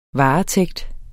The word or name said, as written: varetægt